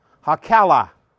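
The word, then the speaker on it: Hacaliah: I'm not trying to show out or anything, but in Hebrew, you're not going to have two consonants together in the same syllable. Okay? So, where you see that you have the H-A-C, so ha, and then the C-A-L, that's a syllable, and the I-A-H. So it's ha-kala. That's three syllables.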